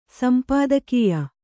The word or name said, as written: ಸಂಪಾದಕೀಯ